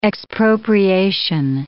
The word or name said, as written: expropriation